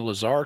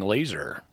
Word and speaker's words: Lazar: And points to Cy Kellett for pronouncing Lazar correctly and not saying laser.